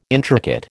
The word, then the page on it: intricate: American English